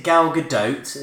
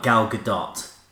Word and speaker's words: Gal Gadot: Is it Gal Gadot? And she said, yeah, pretty much, but it's more like Gal Gadot.